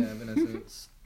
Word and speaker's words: Evanescence: Yeah, Evanescence.